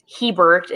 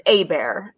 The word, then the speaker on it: Hebert: And, you know, we don't pronounce Hebert, it's Abear, and things like that, right?